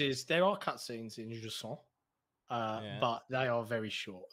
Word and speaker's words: Jusant: Jusant. In their review guide, they literally say, this is how you pronounce it, Jusant.